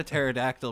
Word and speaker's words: pterodactyl: Thinking about a pterodactyl made me realize like a good rap name would be Silent P.